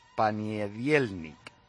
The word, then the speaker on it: Ponedelnik: El máximo goleador de la primera edición de las Eurocopas se llama Víctor Ponedelnik. Dice, en ruso se pronuncia Paniedielnik, que significa literalmente lunes.